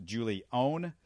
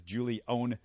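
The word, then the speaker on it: Julie'n: When you look at his name, it looks like Julian. It's pronounced Julione, and that little it's the apostrophe is actually over the E, not between the E and the N.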